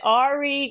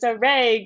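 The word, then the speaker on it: Arey: because we still get Ari Gray and it's like, no, it's a, it rhymes. It's a Ray Gray.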